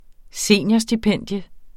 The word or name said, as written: seniorstipendium